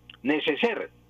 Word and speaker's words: neceser: Neceser. Se escribe necesaire, pero se pronuncia neceser.